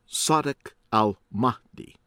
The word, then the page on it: Sadiq Al-Mahdi: American English